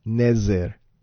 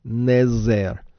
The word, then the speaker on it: Nezer: In fact, it's spelled Nun Zayin Resh, and that's pronounced Nezer, Nezer. Now, the word Nezer is related to Nazarite, which is not to be confused with Nazri or Nazareth or Nazarene.